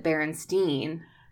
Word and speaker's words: Berenstain: little because my mom pointed out to me, like when I was learning to read, she pointed out, look, it's spelled A I N, even though we pronounce it Berenstein. It's, Interesting. You know, Berenstain.